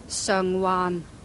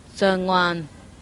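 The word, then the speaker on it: Sheung Wan: However, you wouldn't say that because you'd say it right. You would say Chun Wan. – Chun Wan. Chun Wan. – Chun Wan. Right? And then the next one is Sung Wan. – Sung Wan.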